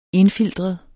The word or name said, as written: INDFILTRET